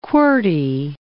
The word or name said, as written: QWERTY